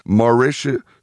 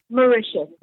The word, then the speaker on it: Mauritius: Is that the correct pronunciation? – Mauritius. – Say it again. – Yes. Mauritius. – Mauritius. Mauritius. – Yes.